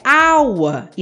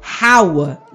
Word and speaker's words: hour: Mas quando falamos a palavra hour, perceba que o H, na verdade, tem um som mudo e acaba emprestando o som criado pela construção aua, né? Que se inicia com um som vocálico. Ou seja, a pronúncia correta é aua e não haua, né?